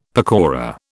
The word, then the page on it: pakora: British English